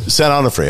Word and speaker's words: San Onofre: And I went to, How do you say that? I've driven by it a million times. – San Onofre? – San Onofre. Okay. Yeah. It's just like it's O-N-O-R-F-E? – Sure.